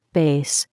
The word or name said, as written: bass